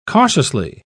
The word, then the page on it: cautiously: British English